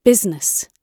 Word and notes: business: British English

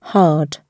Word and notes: hard: British English

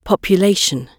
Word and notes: population: British English

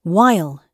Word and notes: while: British English

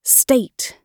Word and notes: state: British English

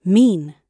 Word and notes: mean: British English

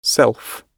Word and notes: self: British English

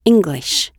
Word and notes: English: British English

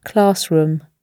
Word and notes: classroom: British English

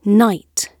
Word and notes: night: British English

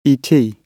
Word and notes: et: British English

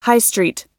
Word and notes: high street: American English

